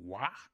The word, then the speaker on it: Waugh: I think that's how you pronounce the last name, Wah, W-A-U-G-H.